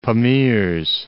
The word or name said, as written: Pamirs